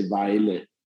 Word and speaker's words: Vejle: So, you know, following on from your sort of youth club that you signed up for, you moved to, now I will try this pronunciation, Via BK. – That was a good trial. In Denmark, we say Vejle. – Vejle. – Vejle, yeah.